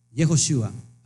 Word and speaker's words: Yehoshua: En realidad, el nombre en hebreo, ahí lo tiene usted a la vista, Yud, Hei, Bat, Shin, Ajin, significa Yehoshua. Así se escribe, así se pronuncia, le puedes poner Yeshua, Yeshua, eh, cuyo valor numérico de Yeshua son 391.